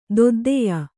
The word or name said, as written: ದೊದ್ದೆಯ